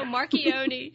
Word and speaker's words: Marchionne: You know, Lisa, one of my favorite stories from your book was the story about Sergio, and help me if I pronounce his last name wrong, Marchionne. Is that correct? – You did good. – Okay. – That's correct.